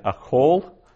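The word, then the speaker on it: achol (אֲכֹל): Notice I said achol, the vowel there for achol, that second vowel is a short O instead of a long A. It's a closed, unaccented syllable.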